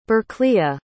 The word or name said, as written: bercleah